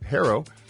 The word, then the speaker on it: Herro: Herro, am I pronouncing that right, by the way? H-E-R-R-O, Herro. What is it? – Herro.